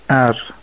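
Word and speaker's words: är: ولی به سوئدی میشه ار. ا یا همون آی با کلا و آر. البته خود حرف آر هم در سوئدی ار تلفظ میشه، ولی کلمه ار به معنای بودن، هستن یا است معنی میده.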